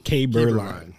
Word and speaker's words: Keberlein: All right, Bryan, help me out. – Keberlein. – All right, Keberlein. Bryan Keberlein. All right. – So, not at all like it's spelled.